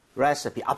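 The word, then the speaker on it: recipe: 좀 익숙한데 우리가 레시피라고 이제 외래어 표기는 하지만 실제로 발음할 때는 레서피에 가깝게 발음을 좀 했으면 좋겠거든요. 오케이. 자, 여러분들도 같이 한번 해볼게요. 레시피보다는 레시피. 레시피. 앞쪽에 강세를 주고 서에 가깝게 갑니다. 레시피.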